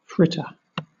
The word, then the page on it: fritter: British English